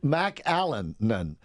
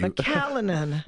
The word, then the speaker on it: McAleenan: Kevin McLennan. – McLennan. – I bet we're pronouncing it wrong. – McAllenan. Let me spell it for you. – McAllenan. – Yeah, there you go. McAllenan. McAllenan or something to that effect.